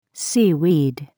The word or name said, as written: seaweed